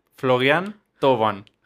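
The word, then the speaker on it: Florian Thauvin: Florian Toban.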